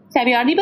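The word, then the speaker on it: Savoiardi: I forget how we pronounce it, but Saviardi? Saviardi biscuits? – Yeah.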